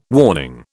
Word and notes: warning: British English

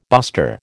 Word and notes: buster: American English

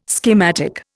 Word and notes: schematic: Indian

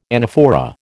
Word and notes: anaphora: American English

